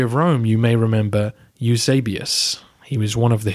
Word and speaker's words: Eusebius: Pronounces his name Eusebius, and I'm sure he knows better than I do.